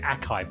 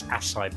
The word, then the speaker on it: acai: I pronounced it acai bowl, but it's acai bowl.